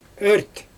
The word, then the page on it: ört: Xyzyl